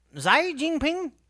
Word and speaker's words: Xi Jinping: Xi Jinping leads a government campaign.